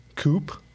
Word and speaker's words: coupe: Yeah, the Brits call it a coupe, and we call it coupe.